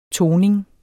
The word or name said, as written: toning